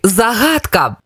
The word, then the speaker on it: загадка: Як правильно, загадка чи загадка? Правильно, загадка. Наголос на перше А.